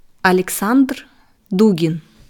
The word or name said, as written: Alexander Dugin